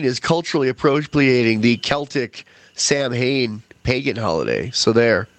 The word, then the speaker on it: Samhain: which is spelled Samhain, and you go to Wikipedia, you'll see that it's pronounced Samhain.